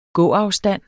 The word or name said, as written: gåafstand